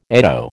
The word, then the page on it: eddo: American English